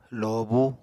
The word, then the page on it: lobú: Apatani